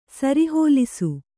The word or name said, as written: ಸರಿಹೋಲಿಸು